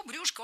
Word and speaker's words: брюшко: Дважды прозвучало брюшко. И это, в принципе, правильно. Сейчас такой вариант стал литературным. Однако, буквально год назад приоритетным было ударение на окончании брюшко. Но теперь за этим словом закреплена двойная норма. Так что можно говорить и брюшко, и брюшко.